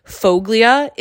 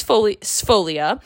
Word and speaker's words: Sfoglia: So we were like, is it Foglia? Is it Sfoglia? Like, we didn't know what we were doing. So I ended up asking the waitress, and it was Sfoglia. The G is silent.